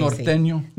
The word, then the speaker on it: Norteño: Norteño.